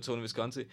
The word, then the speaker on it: Tony Visconti: T- Tony Visconti.